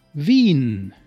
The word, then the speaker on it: Wien: I-E is pronounced E, like in Wien, Vienna, you remember?